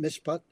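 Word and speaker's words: mishpat (משפט): It's called misfit, that's the that's pronounced mishpat.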